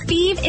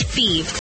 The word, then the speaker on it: Fives: Feeve is spelled F-I-V-E-S, like the number, but pronounced Feeve.